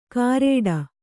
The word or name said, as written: ಕಾರೇಡ